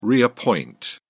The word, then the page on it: reappoint: British English